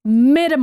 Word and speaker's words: Midi: Really, I'm at Middy Myers. Middy Myers. Can I pronounce that correctly? Middy. M-I-D-I. – I don't know. – M-Y-E-R-S. – It's your name. I hope so. – Yeah. Middy Myers.